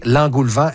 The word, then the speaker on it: engoulevent: Là, Marie-Ève, d'entrée, l'engoulevent, est-ce que je le prononce bien? – Oui, absolument.